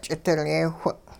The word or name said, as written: ch'idilyee-whing'